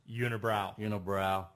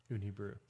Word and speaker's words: Unibrew: Or more commonly known to us Americans as Unibrow. That's how we pronounce it. That's how we're going to say it. But so we're grammarly correct or pronunciationally correct. Unibrew. Yes, Unibrew.